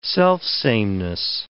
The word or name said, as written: selfsameness